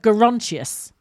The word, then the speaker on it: Gerontius: Elgar's Dream of Gerontius, and it's Gerontius's soul as it leaves his body.